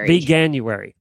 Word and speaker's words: Veganuary: So she posted 26 recipes for your veganair. How do you say it? – Veganuary.